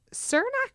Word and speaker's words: Cernak: So at 6, Richard Cernak, I guess, I don't know how to pronounce his name. It's C-E-R-N-A-K. – Yeah, Cernak, I think sounds right.